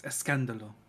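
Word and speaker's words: escándalo: Scandalo. – Mm-hmm. Scandalo. Or I'm, I'm sorry, I didn't say it correctly. It's escandalo.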